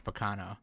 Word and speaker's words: Baccano: It made me want to watch Sirius the Jaeger, and Sirius the Jaeger makes me want to watch Baccano. So it's like... – It's Baccano-san, not Baccano, for fuck's sake. You got to pronounce it right. It's the first syllable that is emphasized.